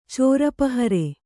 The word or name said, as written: ಚೋರುಪಹರೆ